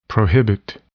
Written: prohibit

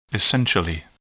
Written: essentially

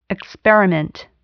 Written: experiment

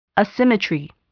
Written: asymmetry

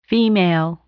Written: female

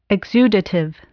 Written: exudative

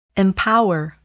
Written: empower

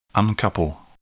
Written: uncouple

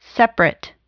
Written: separate

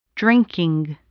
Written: drinking

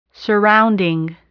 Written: surrounding

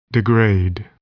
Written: degrade

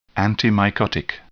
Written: antimycotic

